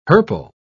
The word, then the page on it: hirple: British English